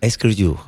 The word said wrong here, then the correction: écriture: ¿Cómo se dice? – Écriture.